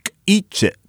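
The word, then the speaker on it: K'iche': But when I call it Quiche, I'm saying it like an American. Oh, yeah, there's this language, Quiche? That's not how it's said. You say K'iche. K'iche. It's an ejective K.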